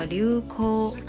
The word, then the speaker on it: 流行: 那这个“ 海亚鲁” 的话呢，其实还有一个读音叫“ 流口” 啊，就是流行的音读哈。